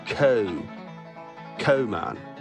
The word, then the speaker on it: coal: See, they don't pronounce the L. So it's co-mine. Co-miner.